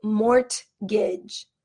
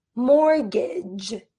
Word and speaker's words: mortgage: the T is silent. When we pronounce this word mortgage, we do not say mortgage. We say mortgage, mortgage.